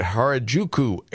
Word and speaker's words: Harajuku: According to this new interview with Allure, Gwen appropriated Japanese culture pretty heavily during her, am I saying it right, David, Harajuku era.